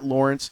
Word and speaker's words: Laurence: If you don't follow me on Twitter or Instagram, it's the same tag, at Lawrence W. Holmes. My first name is spelled L-A-U-R-E-N-C-E, by the way.